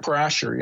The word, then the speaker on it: Parashar: is his name Rick Parashar? How do you say his name? – Rick Parashar, yeah.